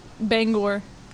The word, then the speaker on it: Bangor: Bangor. I said it right. – You did. – I'm going to mark that in the calendar that I said that right because I have been frowned upon because I said banger before.